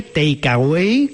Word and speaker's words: Takeaway: Fuimos al Takeaway, que se llama así, pero además que no es Takeaway en inglés. Ajá. Se escribe Takeaway, como se escribiría en español. Takeaway.